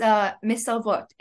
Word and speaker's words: Mesarvot: And it's Mesavot. In Hebrew, this means we refuse.